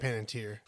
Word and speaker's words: Panettiere: Hayden Panettiere. – Yeah, Panettiere.